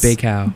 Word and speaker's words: Bacow: Do we know how to pronounce? – Bacow. – Bacow.